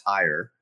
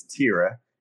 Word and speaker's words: Tyre: So anyways, the ancient location of what we would say is Tyre, T-Y-R-E, but it's Tira is how it's actually pronounced.